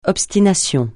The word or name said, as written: obstination